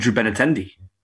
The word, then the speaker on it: Benintendi: And Andrew Benintendi. I might be saying his name wrong. I hope not. – No, yeah, you got it right.